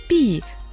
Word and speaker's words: 避: Слово «пи» тоже в четвертом тоне, означает «прятаться», «отойти в сторону».